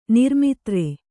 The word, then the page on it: ನಿರ್ಮಿತ್ರ: kannada